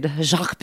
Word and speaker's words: Jacqui: Jacqui Pirl. So it's Jacqui Pirl. – Cool. Yeah. – But it's spelled J-A-C-Q-U-I.